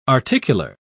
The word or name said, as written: articular